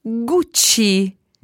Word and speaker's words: Gucci: Gucci.